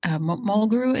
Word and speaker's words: Mulgrew: My mom is a huge Kate Mulgrew.